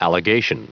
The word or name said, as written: allegation